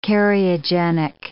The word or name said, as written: cariogenic